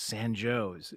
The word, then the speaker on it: San Jose: San Jose is San Joe's.